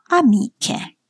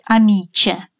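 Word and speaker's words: amiche: Но мы не должны произносить «амиче». Как быть? Поставить разделительный заборчик. «Амике». Поняли?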